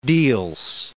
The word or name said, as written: Diels